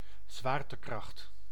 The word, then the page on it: gravitation: American English; /ˌɡɹævɪˈteɪʃən/